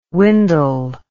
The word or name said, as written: windle